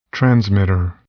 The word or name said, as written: transmitter